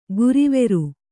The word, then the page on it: ಗುರಿವೆರು: guriveru